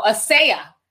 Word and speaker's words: Asia: Asia Muhammad says, hey, sis, again, my name is pronounced, Asaya. not like the content. Oh, but it's spelled like Asia, but it's Asaya.